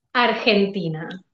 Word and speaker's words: Argentina: It's not, you don't really pronounce the G, right? It's like Argentina, right? – Argentina.